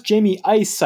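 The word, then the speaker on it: Jamie Uys: I think it's pronounced Jamie Ice, I think, after some research. – That was curious. – Jamie U-Y-S.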